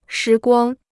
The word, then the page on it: 时光: shí guāng